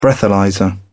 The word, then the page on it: breathalyser: British English